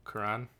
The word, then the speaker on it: Quran: Quran.